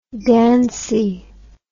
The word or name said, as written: dancy